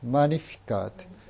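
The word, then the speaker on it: Magnificat: 有名なマニフィカートと呼ばれる賛美ですね。マニフィカート。このラテン語は、英語ではマグニファイと訳します。マニフィカート。まあ、 G の音は、ラテン語では、教会ラテン語では発音しないので、マグニファイ。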